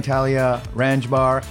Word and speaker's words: Thalia Ranjbar: Welcome to the Hang, Thalia Ranjbar, Cat Cartusciello.